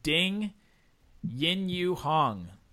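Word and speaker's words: Ding Yanyuhang: And then Ding Yin-Yu Hong. That's how you pronounce the name.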